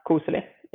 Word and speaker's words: koselig: And isn't there a word in Norwegian, like, again, I don't know about the pronunciation, but kuselig, koselig? – Yeah, kuselig, yeah.